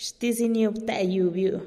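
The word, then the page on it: xtiziniub-te yiubiu: Teotitlán del Valle Zapotec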